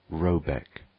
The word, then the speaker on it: Rabaeck: The manor-house, or Herrgard, in question, is to be called R.A.B. A.E.C.K., pronounced something like Robeck, though that is not its name.